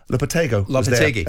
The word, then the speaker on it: Lopetegui: Lopetegui.